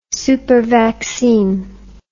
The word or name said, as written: supervaccine